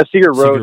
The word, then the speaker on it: Sigur Rós: And is it Sigur Rós? Did I pronounce that correctly? – Yeah, Sigur Rós.